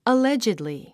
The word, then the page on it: allegedly: əlédʒidli; アレジドリィ